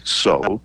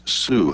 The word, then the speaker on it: Sault: Marie is spelled S-A-U-L-T. – Yes. – Now, if you were French, you would probably say so. And if you were not French, you might say salt. But somehow it's Sioux.